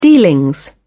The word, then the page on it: dealings: British English